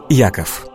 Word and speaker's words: Иаков: В современном русском языке имя Иаков обычно произносится как Яков.